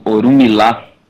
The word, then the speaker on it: Orumilá: E estamos aqui agora para ter um outro bloco aqui, muito legal, com o Paulo César, do Orumilá. Agora falei certo? Acho que agora eu falei certo. – Tá certo.